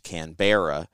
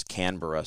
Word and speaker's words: Canberra: I mean, I remember when I got there and I had to take a flight to the capital city, which I pronounced Canberra. And they laughed at me and told me it was Canberra.